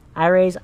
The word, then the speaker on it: Irie: Iray's Eyes Cosmetics. That's spelled I-R-I-E, Eyes Cosmetics.